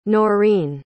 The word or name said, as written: Norine